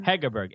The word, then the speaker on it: Hegerberg: Hegerberg. H-E-G-E-R-B-E-R-G.